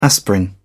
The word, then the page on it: aspirin: British English